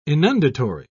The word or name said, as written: inundatory